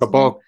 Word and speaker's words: Caboclo: Caboclo.